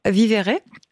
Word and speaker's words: Vivere: Vous avez fondé l'association Vivere. Je le prononce très mal, ce nom, mais Vivere, j'imagine. Donc, comme pour dire vivre. – Oui, c'est exactement ça. C'est du verbe latin, vivere, vivre. Oui.